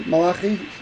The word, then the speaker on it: Malachi: As Malachi, what, is that how you pronounce it? – Malachi? Yeah, Malachi. That's the prophet Malachi, or if you're you know, Malachi. – Malachi. – Malachi is good.